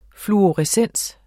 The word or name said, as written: fluorescens